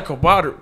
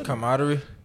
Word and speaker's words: camaraderie: If you ever been to a festival, it's usually a lot of camaraderie. How do you say that word? Did I just say camaraderie? – It's usually a lot of camaraderie.